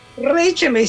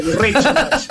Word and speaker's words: Reichenbach: Y hablo como alemán, es Reichenbach. – Pero vos te presentás como Reichenbach. – Reichenbach, sí, yo soy. Sí. Sí. Como si fuera Rodríguez, claro. ¿Viste? Reichenbach.